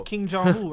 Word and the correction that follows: Kim Jong-un: right? – Kim Jong-un.